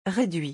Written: reduit

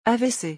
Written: avc

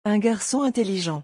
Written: un garcon intelligent

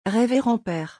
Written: reverend pere

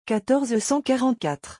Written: 1444

